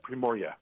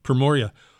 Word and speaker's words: Primorye: I'm probably going to mispronounce this, but the Primorye? – Yep, Primorye. – Mm-hmm. Primorye. Describe where it is and then what it's like, because it sounds fantastic.